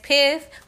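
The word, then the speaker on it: Piph: Like Ebonics, DAT, D-A-T. PIF is not P-I-F, it's P-I-P-H. Yes, P-H still makes the F sound.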